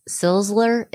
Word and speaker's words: Czeisler: I'm going to say Zeisler. It's spelled C-Z-E-I-S-L-E-R.